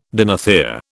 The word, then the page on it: dinothere: British English